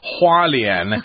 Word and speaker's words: Hualien: Hualien is how it's supposed to be pronounced.